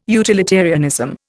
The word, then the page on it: utilitarianism: Indian